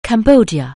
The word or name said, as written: Cambodia